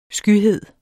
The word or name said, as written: skyhed